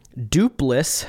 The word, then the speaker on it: Doopliss: This is Dupliss Battle, and that's spelled Doopliss.